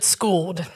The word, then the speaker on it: Skool-Ed: We pronounce it Skool-Ed.